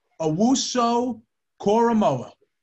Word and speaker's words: Owusu-Koramoah: It's Jeremiah Owusu-Koramoah.